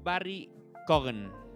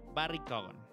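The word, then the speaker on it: Barry Keoghan: Barry Cowan. Barry. Sí se pronuncia así. A ver, vamos a ver cómo se pronuncia. Barry Cowan. Mm, es Barry Cogan. Así se pronuncia, Barry Cogan.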